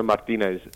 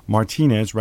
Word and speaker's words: Martinez: There should be no assumption that Roberto Martinez, because Pronounce him with your Yorkshire accent. Just put the emphasis on Martinez rather than Martinez.